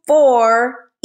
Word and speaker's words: U4IA: Well, this next move, ladies and gentlemen, that she has made ain't going to be too silent because she is the founder and CEO of U4EA, but it's spelled U4IA.